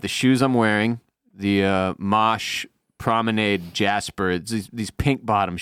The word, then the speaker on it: Mache: It's the Mosh dude, his name is M-A-C-H-E, and it's pronounced Mosh.